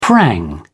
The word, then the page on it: prang: British English